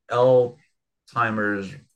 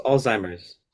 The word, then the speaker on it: Alzheimer's: just pronounced al- Alzheimer's. Alzheimer's. Alzheimer's. – Al- al- Alzheimer's is a Z.